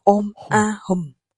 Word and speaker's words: Ôm A Hùm: Ba chữ ấy đọc là Án A Hồng, nghĩa là Ôm A Hùm.